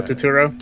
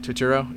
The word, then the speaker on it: Turturro: And then big props to John Turturro. Is that saying his name right? – Oh, Turturro. – Turturro, yeah.